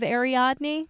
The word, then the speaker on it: Ariadne: Like Ariadne, like a web of Ariadne."